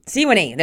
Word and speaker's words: Sewanee: He's a British-born American biologist, author, and professor of biology at Sewanee. Did I say that right? the University of the South in Suwanee, Tennessee. – Sewanee. – There we go. I knew I was saying it wrong.